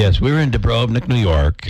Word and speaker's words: Dubrovnik: Dubrovnik is how they say it.